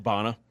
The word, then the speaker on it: Bana: Bana.